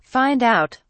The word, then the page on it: find out: British English